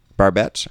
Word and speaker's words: Barbet: I'm not even sure how to say his name. Barbette or Barbet? Schroeder is his name. – I think it's Barbette. – Barbette.